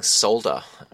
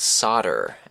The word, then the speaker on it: solder: There's no audio backing track this time, but I have a very quick question about the pronunciation of a certain word, that word being solder, whereas you Americans seem to pronounce it solder, and that is a fact that has always intrigued me.